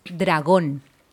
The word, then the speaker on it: dragón: It's just dragón. Can you say it? – Dragón. – Dragón is really close to dragon, but it's just the strongest one. It's the last syllable, so dragón.